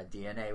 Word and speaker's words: DeNA: How do I say it? Yeah, DNA with an E in the middle.